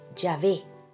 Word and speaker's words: Yahvé: Este tetragramaton YHWH se pronuncia Yahvé y significa yo soy el que soy.